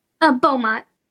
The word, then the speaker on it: Beaumont: Her husband, Ryan, entered the Belmont Hospital. Did I pronounce that right? – Beaumont. – Beaumont, thank you.